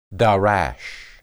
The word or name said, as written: darash